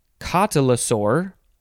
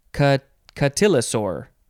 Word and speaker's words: cotylosaur: It is, you can pronounce this a couple ways, cotylosaur or cotylosaur. Cotylosaur or cotylosaur. And it is spelled C-O-T-Y-L-O-S-A-U-R.